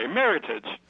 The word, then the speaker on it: Meritage: they chose a word that they thought would be easy to pronounce, Meritage, but in fact, you know, non-snobs, civilians, say Meritage, and it's the snobs who know how to say it. You say it the American way, Meritage.